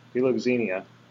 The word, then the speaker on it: philoxenia: And so what you have here is philoxenia, I cannot say it correctly, philoxenia, there we go, philoxenia would be the command for Christians to have brotherly love toward aliens.